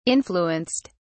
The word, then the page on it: influenced: British English